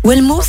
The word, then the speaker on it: Welmo: Alors vous êtes le fondateur de Wellmo, j'espère que je le prononce bien. Hein. Wellmo, c'est ça Exactement.